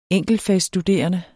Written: enkeltfagsstuderende

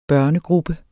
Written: børnegruppe

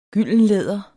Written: gyldenlæder

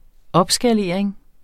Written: opskalering

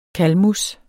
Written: Calamus

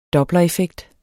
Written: dopplereffekt

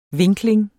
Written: vinkling

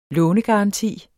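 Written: LÅNEGARANTI

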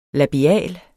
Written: labial

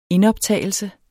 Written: indoptagelse

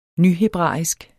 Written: nyhebraisk

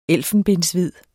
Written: ELFENBENSHVID

